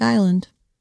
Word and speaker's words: Isla: Well, the S is silent. – Mm-hmm. – Like island. Hello. – Yep, like island. Isla.